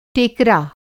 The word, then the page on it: टेकरा: Hindi